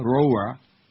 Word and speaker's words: Roura: 14 till 2 at 2 a.m., anti-Castro radio commentator Armando Perez Roa, how do you say that, R-O-U-R-A, Roa? R-O-U-R-A, Roa. – Right. – What is it? Roa. No, not A. There's no A.